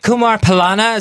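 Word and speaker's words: Kumar Pallana: Kumar Palana, is that how you say it? – Yeah.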